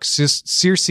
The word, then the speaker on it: Circe: Like, it's adapting to the modern pronunciation. It's saying, look, Circe has become the pronunciation over time. There's a valid justification for it, even in the ancient world, for pronouncing it that way.